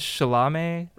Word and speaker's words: Chalamet: Thomas Chalamet rhymes with salami, as John Stanley pointed out in the article in the Chronicle.